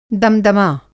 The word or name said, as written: दमदमा